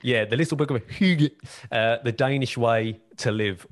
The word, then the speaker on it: hygge: It looks like Heeg, H-Y-G-G-E. I think it's pronounced like Hygge.